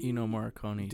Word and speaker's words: Ennio Morricone: Ennio Morricone did the soundtrack.